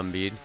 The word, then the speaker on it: Embiid: Oh, yeah, Joel Embiid.